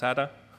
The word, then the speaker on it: SATA: The drives are 750 gigabytes SATA, which somebody told me is pronounced SATA.